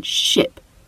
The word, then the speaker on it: ship: Some people really have difficulty in distinguishing between the E sound that you hear in sheep and the I sound that you hear in ship. Sheep. Ship. Now, if you struggle with hearing and pronouncing this particular difference, a really helpful exercise is to practice what they call minimal pairs.